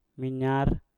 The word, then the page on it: minyar: Mpur